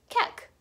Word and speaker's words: Kek: Not only that, but the user ID ends with QEK, which could be pronounced as Kek.